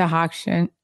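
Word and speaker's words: Hochstein: We hung out with Larsa Pippen, Caroline Stanberry, Lisa Hockshin. Am I saying, how do you say her last name? – Yeah, you're right.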